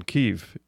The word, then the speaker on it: Kyiv: Kyiv, if you pronounce it the Ukrainian method.